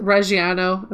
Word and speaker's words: Reggiano: Is that Reggiano? Am I saying it wrong? – You're close enough.